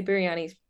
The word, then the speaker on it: biryani: B-I-R-Y-A-N-I. So it's pronounced like biryani or biryani? – Biryani. Biryani. Yeah.